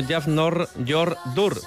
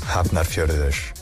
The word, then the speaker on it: Hafnarfjörður: Mañana no nos cortan, Artaleku vuelve a Europa a la competición de Bidasoa con un conjunto islandés que se llama algo así como Jafnarfjörður, pero realmente se pronuncia de la siguiente manera. – Hafnarfjörður, Hafnarfjörður, Bueno, casi parecido a lo que acabo de decir yo.